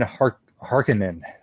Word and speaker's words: Harkonnen: He's playing Baron Harkonnen. That's how you say it. Harkonnen.